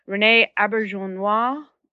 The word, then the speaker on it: Auberjonois: And I will probably mispronounce this, but if I am correct, this is how he told me to pronounce it 2 years ago. Rene Auberjonois is also going to come.